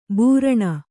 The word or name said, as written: ಭರಣ